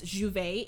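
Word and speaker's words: J'ouvert: And, you know, it's pronounced as Juvet in Trinidad.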